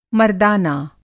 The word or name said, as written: मरदाना